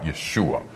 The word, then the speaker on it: Yeshua: It would be pronounced more like Yeshua. In Greek, it's more like the Spanish Jesus.